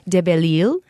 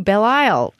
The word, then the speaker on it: De Belisle: I'm sad that the pronunciation of our last name has been Americanized from De Belisle, am I saying that right, Sonia? – Mm-hmm. – To Belisle. She says it loses the flavor and the meaning of the name.